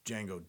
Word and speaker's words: Django: And he's like, Django, D-G-A-N-G-O. And he's like, the D is silent.